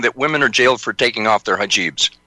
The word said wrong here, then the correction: hijab: Well, the pronunciation is hijab.